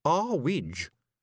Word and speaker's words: Earwig: "It's too high, Mrs. Earwig," said Tiffany. – "It's pronounced Arwidge," said Mrs. Earwig coldly.